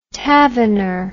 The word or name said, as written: tavener